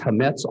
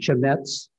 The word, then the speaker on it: chametz: The term they would use was chametz, also sometimes pronounced chametz.